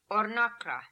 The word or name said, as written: Ornakra